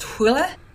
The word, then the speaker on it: Hole: Which, by the way, Wikipedia, this is a Norwegian series, so Wikipedia tells me that his name is pronounced Hule,